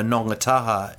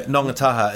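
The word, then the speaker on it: Ngongotaha: But I remember when I used to play for Nonga Taha in New Zealand, one of the great clubs. Used to do Nonga Taha. It's spelled Ngotaha.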